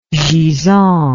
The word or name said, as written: gisant